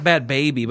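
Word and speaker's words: Bhad Bhabie: I think it's pronounced Bad Baby, but it's spelled... – B-H-A-B-I-E. – Yeah, that's Bad Baby.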